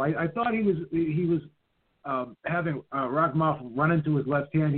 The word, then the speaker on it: Rakhimov: Rakhimov. – Rakhimov.